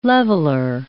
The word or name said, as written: leveler